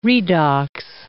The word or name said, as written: redox